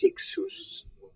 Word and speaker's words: Cixous: Ça s'écrit avec un C. Ça se termine par un S. Ça se prononce Cixous à l'occasion.